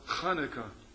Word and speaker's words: Chanukah: Whenever you can say Hanukkah or Chanukah. C-H is ch. Or you see that like Pesach or Chanukah or L'chaim. That's how you pronounce that word, C-H. It's a guttural sound.